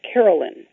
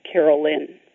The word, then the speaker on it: Caroline: My first name is pronounced Carolyn, even though the spelling, C-A-R-O-L-I-N-E, makes you think it should be pronounced Caroline. Just think of my name as if it were spelled Y-N at the end, and say Carolyn.